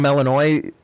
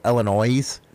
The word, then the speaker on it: Illinois: That's just like when you say we're from Illinois, others from out of state go Illinois. It's like, hey, dumbass, the S is silent.